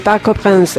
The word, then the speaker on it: Parc des Princes: Parc des Princes, en el Parque de los Príncipes, ¿sí? – Muy bien lo pronunció.